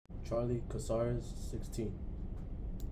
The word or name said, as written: Charlie Cazares